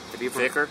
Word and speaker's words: dhikr: would be that what they call in Sufism the dhikr. – Dhikr? – Dhikr. I see dhikr. It's spelled as D-H-I-K-R, the dhikr.